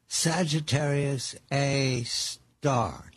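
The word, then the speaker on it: Sagittarius A*: Sagittarius A star. Did I say it correctly? – Yes, that's correct. It's A with an asterisk, but it's pronounced A star.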